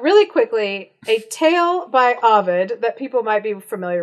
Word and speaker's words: Ovid: And I looked it up, and it is indeed Ovid.